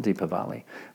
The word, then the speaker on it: Diwali: Diwali, depending on how you want to pronounce it, Deepavali, which is the a wonderful festival of light.